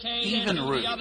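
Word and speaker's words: Evinrude: Even the term "rude" became part of the name Evan Rude, originally pronounced as Even Rude.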